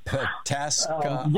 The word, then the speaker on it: Pataskala: Pataskala.